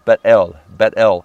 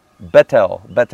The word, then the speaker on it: Bethel: But the pronunciation of Bethel, of Bet-El, house of God. Bet is house. El is Elohim or God. And so Bethlehem, house of bread, right? So Bet-El.